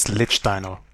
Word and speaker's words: Lichtsteiner: First things first, Trev, it's Lichtsteiner. All right. Oh, damn. – Pronunciation is nothing. I just want to call him Steve. – Oh, my God. – Yeah, Steve. – He was mixing Liechtenstein, the country, and Lichtsteiner together.